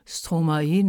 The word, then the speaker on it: Stromae: Und jetzt, na, es kommt schon Summertime, aber lieber doch habe ich mich für Stromae entschieden. Ist der Sänger, der sich ja in der Umkehrung, in der Vertauschung von zwei Silben aus dem Wort Maestro Stromae nennt. Stromae bekannt für Lieder wie Alors on danse oder Papa Ute, wo er ihm fragt, Vater, wo bist du, Papa, wo bist du?